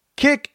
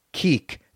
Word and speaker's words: Kik: Kick, it's forever K-I-K and not keek to me.